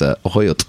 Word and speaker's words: axolotl: Apparently, the more accurate pronunciation, which I'll do a bad job of because I don't speak that language, is ahoyot.